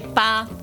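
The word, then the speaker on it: pa (八): L'8 è un altro numero fortunato e si dice pa. – Pa. – Pa. – Pa. – Pa, pa, pa. – Se cambi pronuncia diventa papà.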